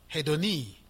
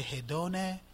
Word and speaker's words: hedone: In this passage, the Greek word translated in English as pleasure is the word hedoni. Some say hedone, some say hedoni. It's a complex word to pronounce, but I'm saying it in Greek.